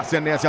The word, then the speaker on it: Senesi: Você pede, a gente vai de Marco Senesi ou Senesi, a pronúncia correta? – Senesi.